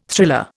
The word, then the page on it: thriller: Indian